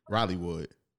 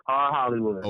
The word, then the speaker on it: R Hollywood: Is it R Hollywood or Rollywood? Rollywood. – No. It's R Hollywood.